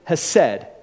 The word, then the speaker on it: hesed: We can pronounce it hesed, H-E-S-E-D.